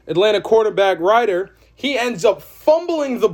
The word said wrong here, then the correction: Ridder: It is Ridder. The quarterback for Atlanta is Ridder, not Ryder. I said that wrong.